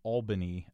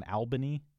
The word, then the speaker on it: Albany: Now, here in Kansas, I would have always pronounced this Albany, but I wonder, are there people who insist on Albany? – Many people, including my wife, who took, I think, 15 years to finally get it as Albany.